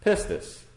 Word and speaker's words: pistis: It's a Greek word, pistis. Spell that P-I-S-T-I-S.